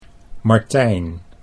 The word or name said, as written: Martijn